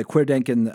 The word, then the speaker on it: Querdenken: How do you pronounce that? – Querdenken. – Okay. Yeah. So the leader of apparently Querdenken 711, Michael Bauweg, he openly used a QAnon slogan in English during a protest in August of 2020.